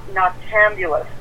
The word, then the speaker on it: noctambulous: And noctambulous. Did I pronounce that perfectly? – Yes, you did. You did it perfect.